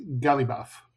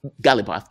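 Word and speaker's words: Ghalibaf: Galibaf. – Galibaf, that's it.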